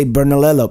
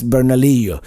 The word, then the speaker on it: Bernalillo: So if you see a double L, it's not pronounced like an L. It's like in English, you would say Bernalillo, but it's Bernalillo.